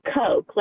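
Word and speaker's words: Koch: It's Coke, like Coca-Cola. – No. Like cocaine. – Right. – Oh, boy. – And it's spelled C-O-K-E? – It's K-O-C-H.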